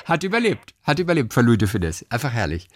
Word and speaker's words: Louis de Funès: Louis de Funès. – Aussprechen, okay. Louis de Funès. – Louis de Funès. – Ja, sehr gut. – Ja, sehr schön. Louis de Funès, scharfes S.